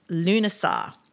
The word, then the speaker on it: Lughnasadh: And Lammas is also known as Lunasar, which I thought I'd mention which is spelt Lughnasadh.